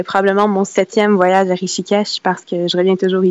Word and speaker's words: Rishikesh: oui, je prononce bien Rishikesh, le nom de la ville? – Oui, c'est bien ça.